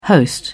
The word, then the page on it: host: British English